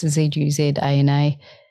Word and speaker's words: Zuzana: It's not your typical Zuzana with an S. It's Zuzana.